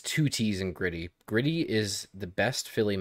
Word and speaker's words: Gritty: It's two T's, but it's pronounced with two D's. If you're from Philly, it gritty instead of grit gritty, it's, it's gritty.